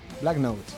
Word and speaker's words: Black Notes: Ah, alguien, seguramente, pues no lo descartes, que dentro de unos años nos diga un grupo, venga, voy a versionar a Black Noise. Notes, se pronuncia. – Black Notes.